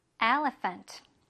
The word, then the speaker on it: elephant: 아까 전에 요거 뭐라고 얘들아? Elephant가 아니라 Elephant. 한번 더. Elephant. Font. 이렇게 하는 게 맞다라는 거지.